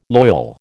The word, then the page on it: loyal: American English